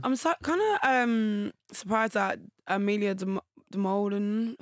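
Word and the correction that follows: Dimoldenberg: I don't know how to say her surname. – Amelia de Moldenberg.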